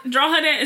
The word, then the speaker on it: Drogheda: Drogheda is spelled D-R-O-G-H-E-D-A. – Like Dunleary. – And it's pronounced Drogheda.